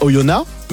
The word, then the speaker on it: Oyonnax: À côté de Bourg, il y a Oyonnax, mais oui. ça s'écrit avec un X à la fin. Si tu vas là-bas et tu dis Oyonnax.